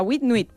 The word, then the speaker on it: huit: Huit. – ¿Qué? – Muy bien. – Huit. – ¿Y noche? – Oui. Huit. La nuit. – Nuit. La nuit. – Nuit, muy bien. O sea, huit, nuit.